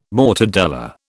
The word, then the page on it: mortadella: British English